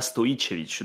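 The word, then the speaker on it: Stojičević: En français, tu vas plutôt dire Stojicevich, et après, en bon serbe, tu diras Stojicevich. Donc, tu as le J que tu prononces I. Voilà.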